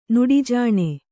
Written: ನಡೆಜಾಣ

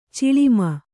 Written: ಚಲುಮೆ

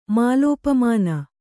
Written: ಮಾಲೋಪಮಾನ

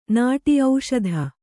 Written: ಓಷಧಿ

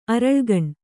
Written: ಅರಳುಗಣ್

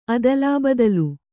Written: ಅದಲಾಬದಲು

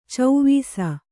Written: ಚವಿಸು

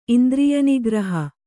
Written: ಇಂದ್ರಿಯನಿಗ್ರಹ

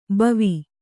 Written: ಭವ

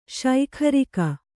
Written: ಶೈಖರಿಕ

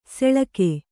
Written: ಸೆಳಕೆ

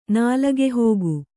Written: ನಾಲಗೆಹೋಗು

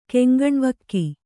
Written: ಕೆಂಗಣ್ಣವಕ್ಕಿ

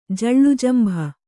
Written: ಜಳ್ಳುಜಂಭ

